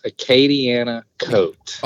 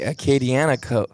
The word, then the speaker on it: Acadianakote: And he is with, how do you say that, Acadian? – Close. Acadiana coat. – Okay. Acadiana coat.